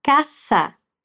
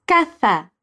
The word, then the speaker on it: caza: E os demais hispanohablantes diriam caça. Como os espanhóis, caza. Como os demais, caça.